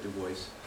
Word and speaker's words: Du Bois: If you hear it again, it is pronounced Du Bois. He pronounced it Du Bois, even though it has a French-looking name.